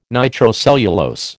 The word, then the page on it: nitrocellulose: American English